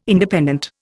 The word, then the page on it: Independent: Indian